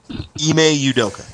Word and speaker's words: Ime Udoka: How, do, how do you say his name officially? Ime Udoka. – You just spell like it I M E and it's it's pronounced Ime? – Yeah.